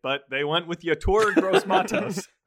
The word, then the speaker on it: Yetur Gross-Matos: And they do their best when they take Yetur Gross-Matos out of Penn State.